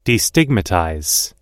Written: destigmatize

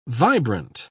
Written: vibrant

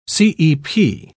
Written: cep

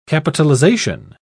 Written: capitalization

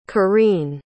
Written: kyrene

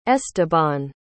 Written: esteban

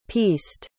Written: pieced

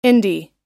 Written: indy

